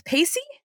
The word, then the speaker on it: Pacey: Pacey? – Yeah, Pacey.